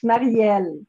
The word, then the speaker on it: Marielle: In French, it's Marielle.